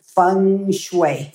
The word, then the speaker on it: feng shui: Now, how do you say feng shui? I've heard so many people pronounce it in so many different ways. The fu, the feng, the hu. It's feng shui.